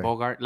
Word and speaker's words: Bogart: Bogart. – Okay. – Bogart. Like, Bogart. I guess the T in English makes it sound more like Bogart.